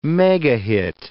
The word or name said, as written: megahit